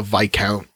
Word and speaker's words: viscount: a viscount under the Poyean constitution. – That's how that's pronounced. Yes. I've only seen that word written down.